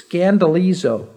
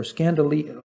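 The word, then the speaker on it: skandalizo: The word offended is literally, if you're going to pronounce it into the Greek word in English, it's scandalizo, or scandalizo, about that word, scandal.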